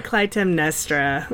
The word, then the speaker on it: Clytemnestra: I pronounce it Clytemnestra. – Clytemnestra.